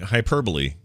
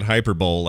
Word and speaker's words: hyperbole: So you're saying hyperbole still a threat? – I'm saying hyperbole is the worst. It will ruin this country. – Well, until we start calling it Hyper Bowl like it's spelled, then forget it.